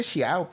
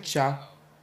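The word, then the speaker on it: ciao: Yo dije xiao, pero ¿cómo se pronuncia, güey? Vamos a ver. Si, pues si es xia. Cállate, pendeja. Es chao. Chao. Chao. es hola en italiano.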